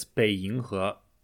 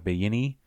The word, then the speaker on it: Beiyinghe: I think it's Beiyinghe. – Beiying, yeah. Everyone was pronouncing it wrong. They were like Beiyini, and like I couldn't find, because it's a village.